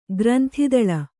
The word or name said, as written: ಗ್ರಂಥಿದಳ